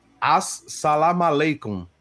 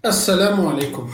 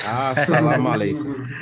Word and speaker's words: Assalamu alaikum: As salam aleikum. Se eu falei certo, é isso aí. – Assalamu alaikum. Assalamu alaikum. – quase, quase.